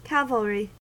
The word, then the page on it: cavalry: American English